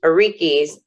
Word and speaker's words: Orikis: In this chapter on Know Yourself, you talk about Orikis. Is that the right way to say that? – That is. – Okay. Orikis.